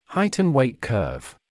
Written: height and weight curve

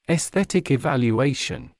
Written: esthetic evaluation

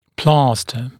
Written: plaster

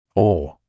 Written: or

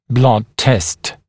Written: blood test

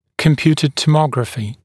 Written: computed tomography CT

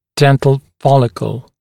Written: dental follicle

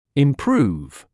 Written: improve

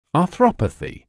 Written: arthropathy

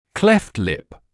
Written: cleft lip

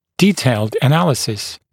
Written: detailed analysis

